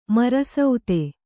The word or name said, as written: ಮರಸುತ್ತು